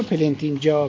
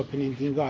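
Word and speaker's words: Plantinga: یا پلنتینجا پلنتینگا هر دو تلفظش هست.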